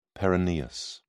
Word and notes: peroneus: British English

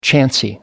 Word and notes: chancy: American English

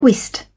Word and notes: whist: British English

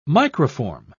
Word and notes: microform: British English

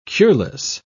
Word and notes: cureless: British English

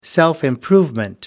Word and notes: self improvement: American English

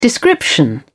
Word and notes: description: British English

